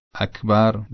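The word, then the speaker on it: أكبر: لذا باید سعی کنیم کلماتی که این حرف درشون به کار رفته مثل اکبر در الله اکبر یا ایاک نعبد و ایاک نستعین، تلفظ این کلمات رو با کاف عربی انجام بدیم و نه با کاف فارسی.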